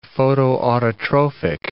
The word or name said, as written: photoautotrophic